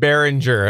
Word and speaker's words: Behringer: You pronounced it Beringer, right? – Yeah. He said he said Beringer. It was Beringer.